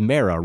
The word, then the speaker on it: Mara: the pronunciation is Mara, right? And she says, yes, it is.